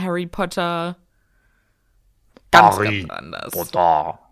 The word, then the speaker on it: Harry Potter: Es ist so, äh, Harry Potter wird auch im Bulgarischen als Harry Potter ausgesprochen.